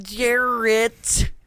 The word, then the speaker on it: Jarrett: And I'm Jarrett.